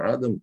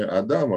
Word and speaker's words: Адам: И третье, значит, что здесь, рядом с этими категориями, это вот такое, вот так оно произносится, Адам, только не Адам, а ударение на первом «а», это то, что мы переводим «несуществование» или «небытие».